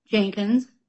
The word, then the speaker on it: Jenkins: Oh, my name is Glenda, G-L-E-N-D-A, Jenkins, J-E-N-K-I-N-S.